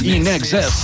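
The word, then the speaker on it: INXS: Com sempre diem, en excés absolutament res és amb el que ells juguen, Inexes. I-N-X-S, i ells doncs, pronunciant aquestes sigles, aquestes lletres en anglès,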